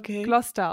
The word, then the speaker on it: Gloucester: Und ich habe noch gesagt, nein, ich glaube, es heißt Gloucester. Leider ist es total beides falsch. Es wird nämlich Gloucester ausgesprochen. – Oh Gott, das ist ja völlig verkehrt. – Aber es ist scheinbar, es sind so ein paar schottische Namen, die man irgendwie total anders ausspricht.